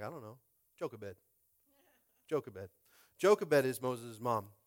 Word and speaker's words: Jochebed: Jochebed.